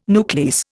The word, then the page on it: nuclease: Indian